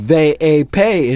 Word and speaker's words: VIP: As he approaches the red rope of the VIP, pronounced ve-a-pe in Spanish, oh my God.